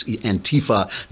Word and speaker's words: Antifa: They put the accent on the wrong syllable. It's Antifa